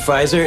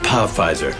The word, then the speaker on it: Pfizer: And Vicky, if there was a P in front of Pfizer, it'd be pronounced Pa-Pfizer.